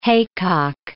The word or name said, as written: haycock